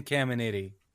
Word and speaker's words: Caminiti: Jason and Jeremy Giambi, Ken Camentini, Ken Caminetti. Caminetti, is that how you say his name? – Oh, you just add an extra T.